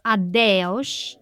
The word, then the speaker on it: adeus: So adios would be in Spanish adios, but in Portuguese it's pronounced adeus.